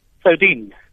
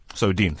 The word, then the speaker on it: Soudien: Please help me with your pronunciation of surname especially. – Soudien. – Soudien, thank you so much.